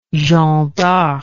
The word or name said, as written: jeanne darc